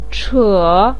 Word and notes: che3: pinyin

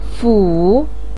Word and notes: fu3: pinyin

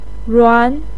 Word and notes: ruan2: pinyin

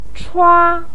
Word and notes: chua1: pinyin

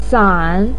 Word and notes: san3: pinyin

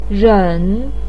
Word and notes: ren3: pinyin

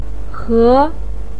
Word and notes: he2: pinyin

